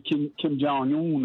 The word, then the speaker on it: Kim Jong-un: But I think Mr. Kim Jong-un, or whatever his name is, if I maybe pronounce it wrong. – No, you got it right.